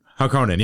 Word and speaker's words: Harkonnen: Harkonnen? Harkonnen, yeah.